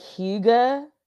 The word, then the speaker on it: hygge: Hygge? Is it hygge? – Oh, it's like hygge. – Oh, hygge. Okay.